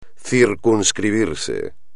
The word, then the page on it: circunscribirse: espagnol